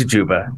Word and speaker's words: Juba: It's Juba.